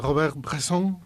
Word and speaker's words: Robert Bresson: Eh, eh, Robert Bresson, pronunciado la francesa, o Robert Bresson,